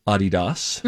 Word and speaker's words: Adidas: Now, this comes as a result of years ago, we learned that Adidas is actually pronounced Adidas.